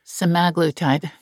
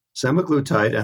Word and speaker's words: semaglutide: and they are singing the praises of semaglutide, or there's another way to pronounce it. – Semaglutide. – Semaglutide. – No, that's the way to pronounce it. Semaglutide, and the brand name is Ozempic and Wegovy.